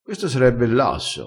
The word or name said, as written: esse l asso